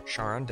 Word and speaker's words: Charon: One of its moons, the biggest one, Charon, or Charon, depending on how you prefer to pronounce it, has been startling scientists.